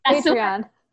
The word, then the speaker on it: Patreon: How do you say it? – Patreon. Yeah, that's it.